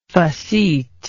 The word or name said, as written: faceteness